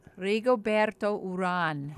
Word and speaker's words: Urán: Rigoberto Urán. How do you say that? – Rigoberto Urán. – Urán. Right. Oh, right.